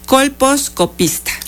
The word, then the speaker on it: colposcopista: La doctora Rosa María Morales Tepatl es médico ginecóloga colposquista. No, colposquista. Bueno, discúlpeme usted, pero bueno, hoy estoy medio trabada. Colposcopista. – Así es. – Ah, ya lo dije bien.